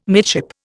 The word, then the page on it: midship: Indian